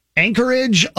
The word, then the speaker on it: Anchorage: Anchorage, Alaska? – You are saying that right.